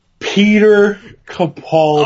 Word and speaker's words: Peter Capaldi: Pete Capolitti. What's his pronunciation? – Peter Capaldi. – Oh, is that how you pronounce that? – Yeah.